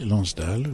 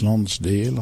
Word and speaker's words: Lonsdale: Oui, Lonsdale, oui, en France, Lonsdale en Angleterre.